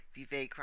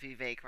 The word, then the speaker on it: Vivek: Vivek rhymes with fake. Or like he says, Vivek rhymes with cake.